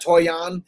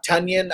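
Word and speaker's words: Tonyan: I, I got to get used to saying that because uh, they said that Toyon uh, is not the way you pronounce his name. It's Tunyon, almost like Funyon. So yeah, I could drop John Brown and pick up Tunyon.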